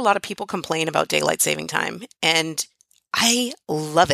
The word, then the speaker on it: daylight saving time: And we're still freezing to death this morning, even with the daylight saving time. Notice I said that right, daylight saving time. I have to make myself say it. There's no S on the saving.